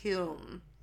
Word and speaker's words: Hulme: H-U-L-M-E.